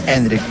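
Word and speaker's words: Endrick: Eso descarta la entrada de Endriki. – Puede ser. – Yo quería ver a Endriki. – No me estás cerrando del todo la pronunciación. – Endrik, ok.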